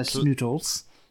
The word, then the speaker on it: Snootles: And then there is Cy Snootles, or Snooties. No, Snootles.